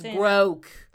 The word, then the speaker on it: groke: Now, am I saying it's Scottish? No. But it's groke. – You probably saying it all the way wrong. – No, I'm saying it right.